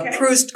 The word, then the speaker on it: Proust: I think it's pronounced Proust.